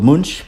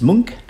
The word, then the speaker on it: Munch: which quite often is called Munch, but that's the wrong pronunciation. It's Munch, M-U-N-C-H, Love, Ghosts and Lady Vampire, a fascinating documentary on the works and life of Edvard Munch.